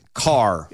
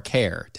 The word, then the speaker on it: Kerr: Because it's spelled K-E-R-R. – But it's Kerr. – It's obviously enunciated Kerr. – Right. – It is, or is spelled Kerr. Kerr. It is enunciated car. – It's car or care, technically. – Yeah. Well, okay. The weirdos across the border in Virginia call it care.